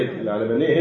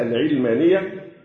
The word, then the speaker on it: العالمانية: بعض الناس ينطقونها العلمانية ويتوهمون أن كلمة العالمانية لها علاقة بالعلم ساينس. لا،